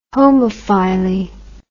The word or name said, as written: homophyly